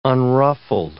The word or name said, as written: unruffled